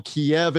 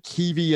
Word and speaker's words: Kyiv: It is spelled Kiev in the U.S. pronunciation, but Kyiv in the spelling in the Ukrainian pronunciation and dialect, where Vitaly Klitschko is the mayor of that city.